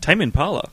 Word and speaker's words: Tame Impala: The Crystal Method, Moby, Tame Impala, is that how you say it? – Tame Impala? – Yeah.